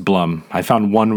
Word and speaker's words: Blum: Is it Blum or is it Blum? – I don't know the answer. – Interrupting here. It is Blum.